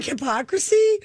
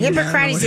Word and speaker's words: Hippocrates: Like hypocrisy? – No, Hippocrates.